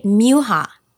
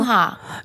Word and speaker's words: Muha: Muha. – No, it's either. Okay. It's all right. – Yeah. We have both. In my family, they say both.